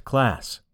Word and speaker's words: KLAS: and the resources available from KLAS, pronounced Class Research, LLC.